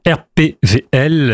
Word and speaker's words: RPWL: RPVL. – W. – Ça s'écrit W, mais on dit RPVL. – Ah oui, parce qu'en allemand, le W se prononce pas, voilà. – Exactement.